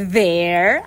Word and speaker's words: their: Olha, a pronúncia é their, assim como there is, there are. There.